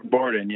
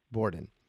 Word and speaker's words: Bourdon: Is it Borden or Bourdon? It, Bor- Borden, yeah. – Borden. Um, and yeah.